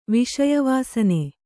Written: ವಿಷಯವಾಸನೆ

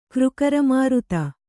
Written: ಕ್ರುಕರಮಾರುತ

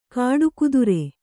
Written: ಖಡುಗಧಾರೆ

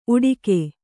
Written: ಉಡಿಕೆ

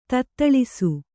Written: ತತ್ತಳಿಸು

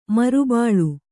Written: ಮರುಬೆಳೆ

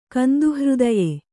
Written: ಕಂದುಹೃದಯ

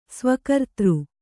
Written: ಸ್ವಕರ್ತೃ

